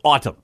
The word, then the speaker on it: Atum: A-T-U-M, not as in fall. – But it's pronounced Autumn. – Yes, but I'm just saying, if you're trying to spell it, A-T-U-M.